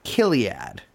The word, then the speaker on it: Chiliad: And Christine goes on to found a company, or goes on to partner with a company called Chiliad. – Yeah, sometimes pronounced Kiliad, which gives me a little bit of a shiver.